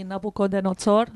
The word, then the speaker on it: Nabucodonosor: Sai, Jasmine stamattina parlava di Nabucodonosor, how do you say in Italian? – Nabucodonosor,